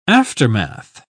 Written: aftermath

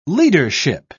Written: leadership

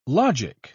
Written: logic

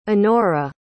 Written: annora

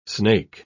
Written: snake

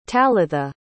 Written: talitha

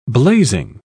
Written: blazing

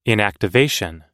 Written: inactivation

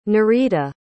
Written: nurita

